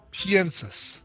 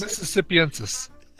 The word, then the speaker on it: mississippiensis: The alligator Mississippi penis. How do you say that? – Yeah, Florida's particular alligator. One of them is alligator Mississippi penis. – Piensis. – Oh, piensis. – Mississippiensis.